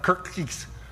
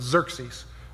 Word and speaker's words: Xerxes: That's how you say that. It's not Kirkkees. The X is actually pronounced as a Z, so it's Xerxes, okay?